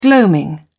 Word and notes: gloaming: British English

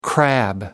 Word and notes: crab: American English

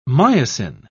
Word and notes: myosin: British English

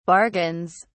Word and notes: bargains: British English

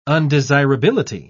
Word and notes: undesirability: British English

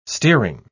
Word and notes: steering: British English

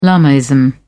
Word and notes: lamaism: British English

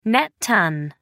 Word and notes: net ton: American English

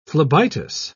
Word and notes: phlebitis: British English

